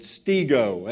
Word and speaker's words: stego: It is the word stego, S-T-E. G long O, okay?